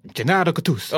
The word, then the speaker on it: Gennaro Gattuso: Can you say it again? – Gennaro Gattuso. Oh, much better.